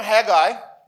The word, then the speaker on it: Haggai: The book of Haggai is actually pronounced Haggai.